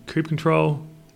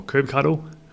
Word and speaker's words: kubectl: Um, in fact, in the blog post, what I do is I go and actually deploy it um, using kube control or kubectl however you guys want me to pronounce it. I'll do it either way.